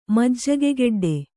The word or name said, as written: ಮಜ್ಜಗೆಗೆಡ್ಡೆ